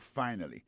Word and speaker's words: finally: Finally, c'est F-I-N-A-L-L-Y. Finally. T'as des prononciations, oui? Finally. Accent tombé sur premier syllabe là. Finally. F-I-N-A-L-L-Y.